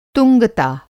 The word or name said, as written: तुंगता